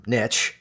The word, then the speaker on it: niche: It's where, as we start our journeys, I'll put this out there, first of all, that yes, as much as I'm a big fan of the term niche, because we prefer that pronunciation because it rhymes with rich,